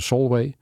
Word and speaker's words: Solway: En dan loop je eh, langs een ja, een, een, monding van de rivier, de Solway, zeg ik dat goed? Ja, de Solway.